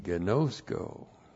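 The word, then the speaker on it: Gnosko (γινώσκω): And then we have our friend gnosko. G-I-N long O-S-K long O. Gnosko.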